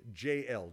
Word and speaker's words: Jael: Eve, Deborah, Yael, you would pronounce her name Jael, J-A-E-L.